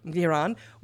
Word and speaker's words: Iran: Iran.